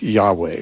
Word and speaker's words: Yahweh: More commonly, you know, Hebrew scholars would, I think, endorse a pronunciation more like Yahweh, which is, you hear that much more often now by those who are trying to be authentic.